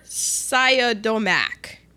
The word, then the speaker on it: Siodmak: Robert Siodmak.